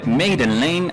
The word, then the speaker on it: Maiden Lane: Maiden Lane, así se escribe, Maiden Lane, así se pronuncia.